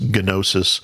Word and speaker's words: gnosis: If you've ever heard that word, gnostic, it's, you actually pronounce the gamma, so it's gnosis.